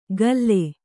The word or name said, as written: ಗಲ್ಲೆ